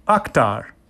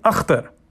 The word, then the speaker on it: Akhtar: "So Akh, like octopus?" – "That works." – "But is that how you say it? Where you're from? Where are you from?" – "Pakistan." – "Pakistan. – And we pronounce the name differently there." – "I'm talented. I can say it right." – "So we say Akhtar." – Father reverted to the native KH guttural sound that no white American in his experience had ever been able to master.